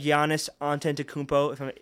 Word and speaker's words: Giannis Antetokounmpo: They obviously have Giannis Antetokounmpo, if I'm saying that correctly. I usually do. – Yeah, that was pretty good.